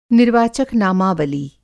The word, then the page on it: निर्वाचक-नामावली: Hindi